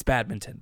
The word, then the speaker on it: badminton: It's badminton, by the way. You do pronounce the N.